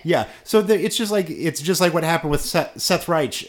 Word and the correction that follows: Seth Rich: Seth Rich.